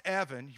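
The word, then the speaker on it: Evan (אבן): So when Jesus says, I am this stone, the stone in Hebrew is pronounced Evan. If you know anybody named Evan, it means stone.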